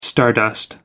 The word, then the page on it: stardust: American English